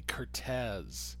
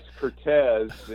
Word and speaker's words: Curtiz: And the first thing I learned was how to pronounce it correctly, Curtiz, which I'll try. – Yeah, it's Curtiz, and, you know, it's interesting...